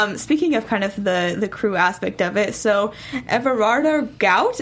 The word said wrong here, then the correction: Gout: is that how you say his name? – Gout, actually. Goot. I said Gout too.